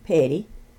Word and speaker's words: Petty: My name is Connie Avon Petty. It's C-O-N-N-I-E A-V-O-N-N-E P-E-T-T-Y, and I live near Delight, Arkansas, in Pike County.